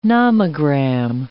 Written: nomogram

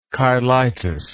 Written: cheilitis

